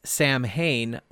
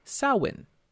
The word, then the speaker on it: Samhain: Now, it is spelled by Western standards as Samhain, but it's officially pronounced Samhain. – Okay. Well, Samhain. – Blame the Gaelic. – Samhain. It's Gaelic.